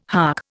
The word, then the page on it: hark: Indian